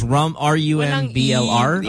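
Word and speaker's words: Rumblr: And it's spelled like Tumblr, only with an R. – You mean the R, so it's Rumbler? – Yes, there you go.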